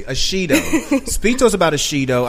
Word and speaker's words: Ashido: Ashido, am I saying it correctly? – Ashido, yeah.